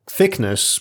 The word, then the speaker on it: Thickness: I swear I didn't pick this story for the names, but Captain, it's actually Thickness, E-S-S-E. And you're always saying, Captain Thickness, it's Thickness. Pronounce it Thickness, please.